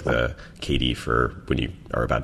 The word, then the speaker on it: ceilidh: We have a dance once a year called a ceilidh, which is spelled C-E-I-L-I-D-H because it's an old Scottish word and it's kind cool.